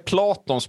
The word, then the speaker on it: Platon: Pelaton, är det Platons brorsa? Pelaton. – Uttalade, sa jag så? – Du sa Pelaton. – Platon, mattan. Ja.